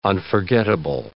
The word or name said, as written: unforgettable